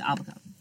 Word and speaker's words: Abaco: Are you sure it's not pronounced Abaco? – Abaco. – No, or Abaco? – It's Abaco. – Abaco? – Abaco. – Abaco? – No, it's Abaco.